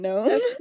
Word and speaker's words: gnome: We have Effrey, like the silent G in Gnome. Because it's a gnome.